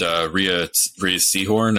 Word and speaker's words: Rhea Seehorn: We haven't even mentioned Rhea Seehorn. Am I saying her name right? – Oh, yeah.